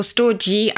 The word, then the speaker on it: storge: All the possibilities of love can be summed up in storge. Is that how you pronounce it? – Yes, or storge. I think it's soft or hard G.